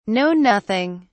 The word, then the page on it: know nothing: British English